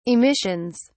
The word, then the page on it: emissions: British English